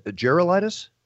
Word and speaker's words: Gerulaitis: Is Gerulaitis? – Yes.